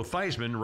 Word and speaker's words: Theismann: Joe Theismann rhymes with Heisman when he was at Notre Dame.